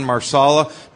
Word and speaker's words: marsala: Tonight we had chicken marsala.